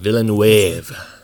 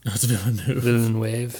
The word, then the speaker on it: Villeneuve: Villeneuve. – Villeneuve, is that it? – Yeah.